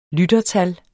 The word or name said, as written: lyttertal